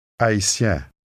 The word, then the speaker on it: Haïtien: It has a nasal sound to it. Try repeating it after the speaker. – Haïtien.